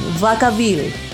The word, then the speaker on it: Vacaville: A ver, es una banda estadounidense de metal alternativo formada en, se pronuncia así, Vacaville, la villa de la vaca. – ¿Vacaville? – Sí, es en serio.